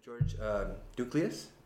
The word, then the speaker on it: George Douklias: Yeah, today I'm sitting here with George Douklias.